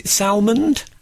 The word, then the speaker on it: Salmond: Now, how do you say Alex? Is it Salmond? – Oh, I always just say salmon.